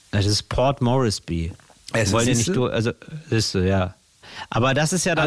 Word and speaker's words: Port Moresby: Moresby, Port Moresby.